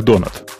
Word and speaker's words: .NET: Произносится как донат.